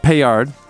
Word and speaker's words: paillard: So what I ended up making was lemon chicken paillard, right? – Yes.